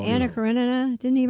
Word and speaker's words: Karenina: Oh, no, you said it right. It's Karenina. You're right. – Thank you. – Karenina.